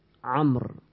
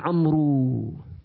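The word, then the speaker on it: Amr (عمرو): They put Waw in the end of Amr. That wow is silence. It's just to differentiate between. When you see that wow, you'll pronunciate it Amr. Without wow, Umar. Unfortunately, some Arab nowadays, they don't know this. They call their children Amru. There's no Amru in Arabic.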